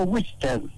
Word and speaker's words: Wichter: La historia en realidad de una persona, una persona polaca, Francisco Wichter. W-I-C-H-T-E-R, Wichter. Seguramente vos lo pronunciarás. – No, no, es Wichter, lo que pasa es que yo conozco al titular de la juguetería Tommy.